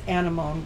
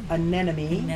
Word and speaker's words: anemone: It's not pronounced anemone, guys. It's anemone.